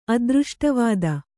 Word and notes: ಅದೃಷ್ಟವಾದ: adřṣṭavāda

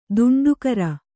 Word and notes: ತಂಟೆಕೋರ: taṇṭekōra

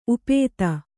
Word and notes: ಉಪಾಧಿ: upādhi

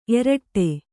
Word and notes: ಏರಿಡು: kannada

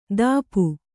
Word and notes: ಧಾಪು: Kannada

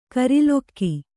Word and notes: ಕೂರಲಗು: kannada

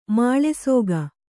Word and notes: ಮುಳ್ಳುಸೀಗೆ: muḷḷu sīge

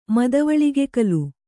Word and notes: ಮದವಳಿಗೆಕಲು: madavaḷigekalu

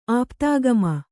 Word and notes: ಆಪ್ತಾಗಮ: Kannada

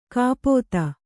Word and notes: ಕಪಿತೆ: Kannada

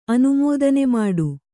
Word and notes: ಅನುಮೋದನೆಮಾಡು: kannada

